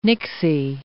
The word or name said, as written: nixie